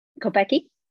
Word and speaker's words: Kopecky: how do you say Lotte's last name? Kopek? Kopecky. Kopecki. It's the Kopecky effect.